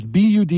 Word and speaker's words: Buda: It's Buda. It looks like Buddha, right? But they pronounce it Buda because they're beautiful there, I guess.